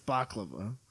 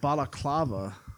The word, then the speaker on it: balaclava: That's baklava. A balaclava, I think, is how it's pronounced.